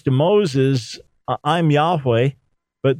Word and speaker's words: Yahweh: So to catch up those just tuning in now, we best understand the pronunciation of God's name as revealed to Moses as Yahweh, not Yehovah or Jehovah, although we're not dogmatic about that and we don't divide over that.